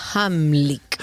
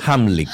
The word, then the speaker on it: Heimlich: Ah, mira, y dice Cecilia que se pronuncia Hamlick. – Hamlick.